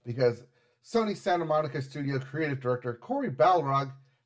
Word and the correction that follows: Barlog: has... – Barlog. He's not the Balrog.